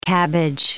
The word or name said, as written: cabbage